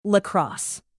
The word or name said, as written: lacrosse